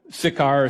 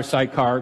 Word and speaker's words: Sychar: And you see Galilee up north, and right in the middle in the gray area there is Samaria, and there's Sychar or Sychar, can be pronounced really either way, right in the middle of Samaria.